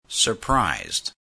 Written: surprised

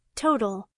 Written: total

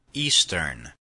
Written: eastern

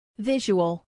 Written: visual

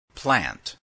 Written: plant

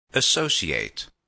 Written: associate

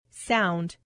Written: sound

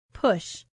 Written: push

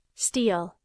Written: steal

